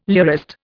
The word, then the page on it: lyrist: Indian